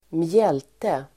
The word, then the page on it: mjälte: [²mj'el:te]